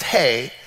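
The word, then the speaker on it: hey (ה): 5 is the letter, it's the letter hey. It's pronounced hey.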